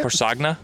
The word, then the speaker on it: Corsagna: C-O-R-S-A-G-N-A? – That's how I would say it. – Corsagna? – Yeah. – Corsagna Deli. I will be honest with you. I don't think I've ever been to Corsagna.